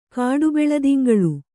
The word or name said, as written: ಕಾಡುಬೆಳದಿಂಗಳು